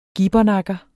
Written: gibbernakker

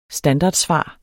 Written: standardsvar

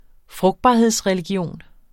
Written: frugtbarhedsreligion

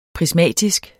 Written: prismatisk

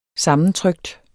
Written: sammentrykt

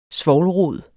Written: svovlrod

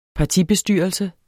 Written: partibestyrelse

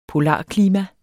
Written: polarklima

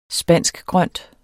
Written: spanskgrønt

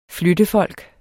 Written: flyttefolk

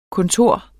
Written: kontor

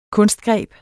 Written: kunstgreb

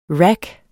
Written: rack

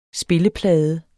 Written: spilleplade

